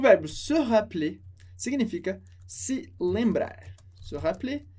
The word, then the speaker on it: se rappeler: A gente escreve com só um L, porque o som é se rappeler, se rappeler. Isso que você vai ver com até o R mais pra frente, que a gente tira o E e pronuncia simplesmente se rappeler. Claro que na escrita ele está ali, sempre presente, mas como tem só um R, a gente pronuncia o E muito rápido.